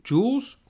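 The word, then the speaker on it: Jhoulys: At Yankees versus Chacin, Chacin, Jules, Julis, how do you say his name? – Jules Chacin. – It can't be Jules. – Yeah. It's a silent H.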